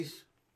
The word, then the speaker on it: Helles: Bad with all the styles, but somebody says this is actually a Hell's Lager. – Helles? – Helly? Is that what I'm pronouncing? – Helles? I would say close.